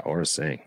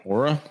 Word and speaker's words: Aura Sing: Aurora? – Yeah, Aura Sing. – Aura? I always say it right.